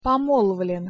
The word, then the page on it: pomolvlen: Russian